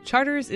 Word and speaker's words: Chartres: It's probably pronounced Chartres, but everybody pronounces it Charters. – I'm from Tulane's Anthropology Department. Charters is the name of a city in France, and it's pronounced Chartres in France.